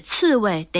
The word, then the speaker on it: 刺猬: 未及的未是刺猬的猬，左边反犬旁，右边胃口的胃。 – 是的，我一直以为这个字只用在刺猬，而且刺猬得读轻声。 – 是的，刺猬得读轻声，但这个字原本读作第四声未。